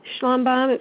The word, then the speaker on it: Schlumbohm: Schlumbohm. It's spelled S-C-H-L-U-M-B-O-H-M.